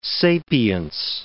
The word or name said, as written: sapience